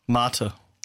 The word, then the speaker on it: Mate: And Club Mater, is that how you say it, or Mate? – Mater. – Mater.